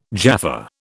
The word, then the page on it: Jaffa: British English